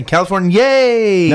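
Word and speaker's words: Californiyay: which is spelled like California, but yay at the end. – Did that throw you off? – Great. – It is. It's exciting. California, yay!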